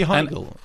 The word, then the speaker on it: Heigl: It's got to be Heigl.